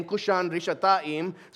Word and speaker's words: Kushan Rishathaim: In Hebrew, Mesopotamia there is the longer name is given, which is Aram Naharaim, which rhymes with his name, Kushan Rishathaim.